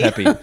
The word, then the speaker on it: Pepi: Is it Pepi? – Pepi. It's Pepi. – Pepi.